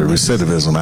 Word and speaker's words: recidivism: recidivism. Did I say that right? – Yes, yeah, you did. – I said recidivism, absolutely.